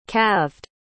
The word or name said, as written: calved